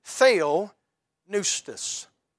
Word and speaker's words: theopneustos: Now, that Greek word's only found one time, and it's the Greek word is actually a compound word, theopneustos. I pronounce that right, I know that.